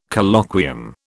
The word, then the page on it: Colloquium: British English